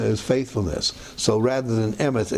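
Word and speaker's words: emeth (אמת): Several words for truth, emeth is the usual word for truth in the Old Testament, not emet, but it's spelled like that, E-M-I-T, but it's a long T-H sound, emeth, and it means truth.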